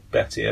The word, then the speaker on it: Bette: Is it Betty or Bette? – Betty, okay. – It's spelt... – Spelt B-E-T-T-E. So okay. It's Bette, but...